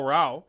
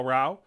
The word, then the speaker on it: Rau: Oh, we had this recent signing earlier this week, Kyle Rau. That's how I'm told it's pronounced. It's like bow of a ship, Kyle Rau.